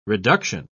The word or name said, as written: reduction